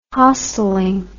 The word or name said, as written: hosteling